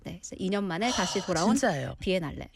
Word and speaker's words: Biennale: How do you pronounce it? – Biennale. – Biennale.